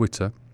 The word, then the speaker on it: Witter: Rick Witter rhymes with shitter.